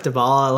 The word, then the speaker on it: Dybala: Dybala.